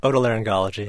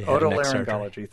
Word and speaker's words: Otolaryngology: I can never pronounce this, doctor, and I bet you can. – Otolaryngology. – Otolaryngology. Thank you very much.